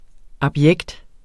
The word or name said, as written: abjekt